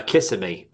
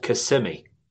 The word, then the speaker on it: Kissimmee: Yeah, a lot of people can't pronounce it right. They always say Kissimmee, but it's called Kissimmee.